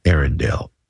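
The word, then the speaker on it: Eärendil: But they spell it E-A-R-E-N-D-I-L. Arendelle.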